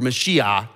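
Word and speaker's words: Mashiach (משיח): If you're saying Christ, you're saying Messiah, or Mashiach is how they would pronounce it today in Hebrew.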